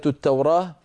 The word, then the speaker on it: التوراة: كلمة التوراة وصلا بالتاء ووقفا بالهاء، لابد من الوقوف عليه بالهاء.